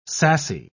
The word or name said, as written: sassy